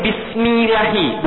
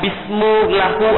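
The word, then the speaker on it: Bismillahi (بسم الله): Tapi kenapa bacanya ini Bismillahi, bukan Bismillahu atau Bismillahu? Kenapa Bismillahi? Pokoknya kalau Bismillahu nggak enak Ustaz.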